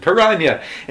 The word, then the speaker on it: piranha: as he calls them, which is the proper Portuguese pronunciation, piranha. And like, it's just so weird.